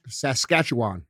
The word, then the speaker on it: Saskatchewan: Lich is a former fitness instructor from Saskatchewan, of however the fuck white people pronounce that. – Saskatchewan.